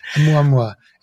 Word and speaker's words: Oumuamua: I think that's the correct way of saying it. Oumuamua. It's a Hawaiian word and it means scout.